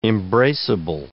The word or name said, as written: embraceable